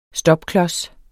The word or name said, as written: STOPKLODSEN